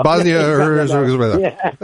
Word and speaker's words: Bosnia-Herzegovina: Bosnia-Herzegovina.